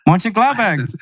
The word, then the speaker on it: Mönchengladbach: You said it right. – I did. – Mönchengladbach. – Mönchengladbach. Okay. I just wanted to not butcher it, so I had to go syllable by syllable almost.